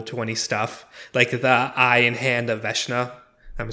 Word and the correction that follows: Vecna: Isn't it Vecna? – Vecna. – Why did I think it was Veshna?